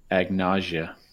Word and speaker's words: agnosia: No, I think I read that wrong. That's not how you say it. It's probably agnosia. Agnosia.